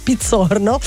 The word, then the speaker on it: Pizzorno: ovviamente insomma il cognome dichiaro origine italiana, per cui andrebbe letto in cognome italiano però con una pronuncia americana, no vabbè, lo diciamo direttamente in italiano, Pizzorno.